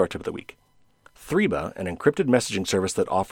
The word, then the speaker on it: Threema: Anyway, there was another one that I don't often talk about called Threema, and that's spelled out like T-H-R-E-E and then M-A, Threema.